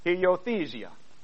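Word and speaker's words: huiothesia: And the word adoption, in the Greek, the word is heiothesia. Did I say it right? I did, George? Yes. Heiothesia.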